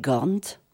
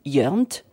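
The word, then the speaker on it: Ghörnt: Et pour l'heure, je vous propose d'écouter les Gornt ou Yernt. Ça s'écrit G-H-O-R-N-T avec deux petits trémas sur le O, comme j'aime. Non pas le truc pour maigrir, mais les petits trémas, un peu comme en Suède.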